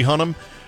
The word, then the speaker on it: Hunnam: If you haven't seen this show where Charlie Hunnam, that's how you say his name, Charlie Hunnam, played Ed Gein in the latest season of Ryan Murphy's Monsters series.